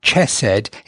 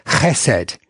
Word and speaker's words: chesed: And the way you pronounce this word is you have to say it with a hard C. It's not chesed, it's chesed. So it's got slightly guttural, chesed.